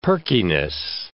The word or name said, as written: perkiness